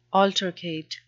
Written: altercate

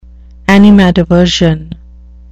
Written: animadversion

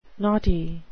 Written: knotty

